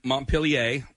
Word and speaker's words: Montpelier: Okay, so Montpelier. – That's how you pronounce that. – Yeah, okay.